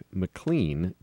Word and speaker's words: McLean: But as it turns out, our executive producer Mike Stark did a little more digging and found out that the accurate way to pronounce the guy's name, in fact, the way his family pronounces it, is in fact McLean, just like you said originally, Chris Martin.